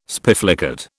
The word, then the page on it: spiflicate: British English